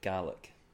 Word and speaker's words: Gaelic: Gaelic or Gaelic? – Gaelic. – So it's Gaelic. – Yeah. It's pronounced Gaelic, but there's no R. So like you get Gaelic, like the Irish Gaelic.